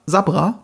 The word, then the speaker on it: Sabra: Und natürlich ist die aktuelle politische Lage, ich verstehe auch ein bisschen, dass sie zurückrudern bei Sabra, heißt ja eigentlich, ne? Sabra, Entschuldigung. – Ja. – Ach süß, ich hätte das Englisch ausgesprochen. Sagt man auch in der englischen Fassung dann Sabra? – Ja.